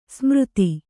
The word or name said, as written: ಸಮರ್ಥ